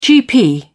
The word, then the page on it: gp: British English